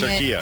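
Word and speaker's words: Türkiye: My entire lifetime, we have been incorrectly pronouncing turkey. – Because we've been saying turkiya.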